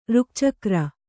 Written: ರುಕ್ಚಕ್ರ

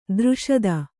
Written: ದೃಷದ

